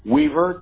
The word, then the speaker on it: Weaver: Weaver, W-E-A-V-E-R.